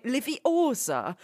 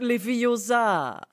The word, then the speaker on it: Leviosa: Die Betonung liegt bei Leviosa und nicht Leviosa.